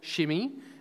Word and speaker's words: Shimei: So we'll meet Ziba and Shimei, who I'm going to call Shimei because I know it seems to be pronounced Shimei, but I had a look at the Hebrew, and from what I can tell, Shimei is okay, but I'm not going to keep doing that the whole talk, so don't worry.